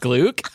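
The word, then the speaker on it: gløgg: Wait, oh, gluk? Is it really pronounced gluk? – That's what Wikipedia says.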